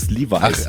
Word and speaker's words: Levi's: Levels? Das sagt mir gar nichts. – Levels, Levi's. – Levi's, so wird man es ja aussprechen.